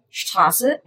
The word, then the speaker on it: Straße: It's called an SZ, and it's pronounced like an S or a double S. So, for example, Straße and heißen.